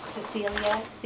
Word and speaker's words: Cecelia: Cecilia, C-E-C-E-L-I-A. My last name is Doucette, D-S, and David, O-U-C-E-T-T-E. – Jean Dolgan, G-E-N-E.